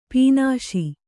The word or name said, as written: ಪೀನಾಸಿ